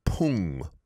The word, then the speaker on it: 풍: The second syllable of storm, pung, pung, it's an iung. So make sure the iung sound, ung, is pronounced there.